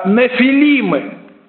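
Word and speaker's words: нефилимы (נפילים): По-еврейски слово «исполин» или «богатырь» произносится как «нефилимы». «Нефилимы».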